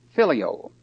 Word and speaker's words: phileo: And it's also spelled and pronounced agapo, A-G-A-P-E or A-G-A-P-A-O, agape or agapo. The next one is filio, P-H-I-L-E-O, filio.